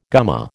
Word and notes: gumma: American English

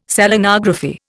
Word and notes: Selenography: Indian

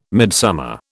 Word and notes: midsummer: British English

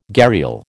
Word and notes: gharial: American English

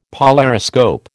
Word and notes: polariscope: American English

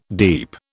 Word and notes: deep: British English

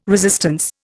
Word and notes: resistance: Indian

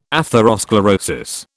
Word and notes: atherosclerosis: British English

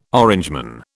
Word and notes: Orangeman: British English

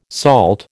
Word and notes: SALT: American English

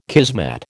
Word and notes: kismet: American English

